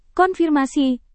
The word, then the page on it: konfirmasi: indonesian